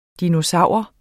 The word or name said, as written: DINOSAURER